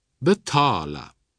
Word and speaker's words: betala: Wie im Deutschen wird also folgendermaßen betont: Tiga, schweigen, aber förtiga, verschweigen. Ebenso tala, talet, talare, aber betala.